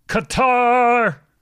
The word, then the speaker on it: Qatar: I actually prefer pronouncing it cutter, but people get tense when you do that. – Isn't that how it's actually pronounced, though? – You can pronounce it either way. Like, legitimately, it is pronounced either way.